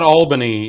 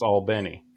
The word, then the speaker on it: Albany: It's not Albany in Georgia. – Right. – Jessica. It's Albany. – That is right.